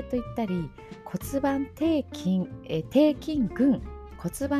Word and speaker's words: 骨盤底筋: えー、骨盤底筋肉の筋と書いて骨盤底筋と読みます。